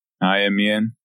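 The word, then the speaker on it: Aimian: But that's how it's pronounced in the audiobooks. – Amian.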